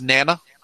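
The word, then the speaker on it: Nena: I believe it's pronounced Nana.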